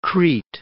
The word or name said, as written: Crete